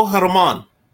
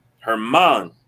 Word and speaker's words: Germán: Come on, pronounce it for me, so I don't mess it up. It's not Domingo Herman. Herman. Yeah.